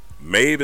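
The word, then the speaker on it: Maebh: Maeve is spelled M-A-E-B-H. I don't know how the hell that's Maeve, but I did look it up. That is how it's said, so I'm gonna leave it right there.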